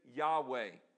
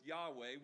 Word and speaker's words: Yahweh: It's Y-H-W-H. Y-H-W-H. In Hebrew, they didn't have vowels. So how do we pronounce this word? We have added our own vowels to come up with Yahweh. We've added our own vowels to come up with Jehovah. Jehovah would be J-H-V-H.